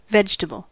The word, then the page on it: vegetable: /ˈvedʒ.tə.bl/; /ˈvɛdʒətəbəl/; /ˈvɛdʒtəbəl/; /ˈvɛtʃtəbəl/